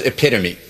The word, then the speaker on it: epitome: This word for a perfect example doesn't rhyme with home. What is epitome?